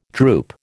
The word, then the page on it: droop: American English